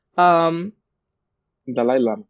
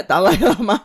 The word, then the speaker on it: Dalai Lama: Dalai Lama. – And, yeah, Dalai Lama.